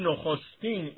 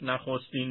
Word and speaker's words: نخستین: نخستین این باید تلفظ بشه و نه اونچنان که معمول ما هست نخستین میگیم.